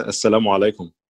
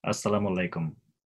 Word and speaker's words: assalamu alaikum: Uh, uh you can say uh, assalamu alaikum. Assalamu alaikum. Uh, assalamu alaikum. – Assalamu alaikum.